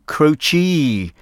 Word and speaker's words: Croce: Singer-songwriter Jim Croce, I think is how most people pronounce his name, but I believe it's not actually the official pronunciation. It should be Jim Croce, with time in a bottle.